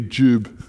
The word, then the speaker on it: Job: And we pronounce it Job, not Job, because the O is a U in Hebrew. So it should be Jube, but I can't do it that way, so it's Job, okay?